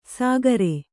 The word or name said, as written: ಸಾಗರೆ